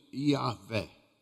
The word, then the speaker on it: Iavé: É impronunciável, porque na língua hebraica não tem vogais. O nome de Deus é Y H W. Y H e um W. Isso é o nome da divindade. E aqui tem um fonema, né? A gente pronuncia Iavé.